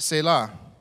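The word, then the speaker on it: Selah (צלע): Now, the Hebrew word for rib is T-S-E-L-A. Obviously, it's not in English. It's Hebrew letters, but that's the English equivalent, and it's pronounced Selah.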